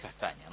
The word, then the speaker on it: Castagna: Exactamente, que en la pronunciación es Castaña, ¿no? Y por eso justamente después lo llevaron a Castaña con Ñ, pero sí.